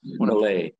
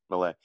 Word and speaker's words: Milei: Is it Milei? – Milei.